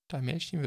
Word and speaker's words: Dalmatian: Generell bei diesen Monster Jam Geschichten ist der Max D raus, die 42119 neu und dafür haben wir neu den Dalmatina, Dalmatian, Dalmatian wird es auf Englisch ausgesprochen, wie dem auch sei, und den äh, Dragon.